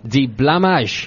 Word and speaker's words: die Blamage: Die Blamage. – What? – You have to say it like that. – What is that? – Die Blamage.